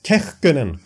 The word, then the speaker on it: Kähkönen: Nej, då finns det ju mer såna här Kapokakonen. – Ja, Kähkönen skulle ja. Jarko vilja att, eller det där var, jag slaktar säkert i uttalet också.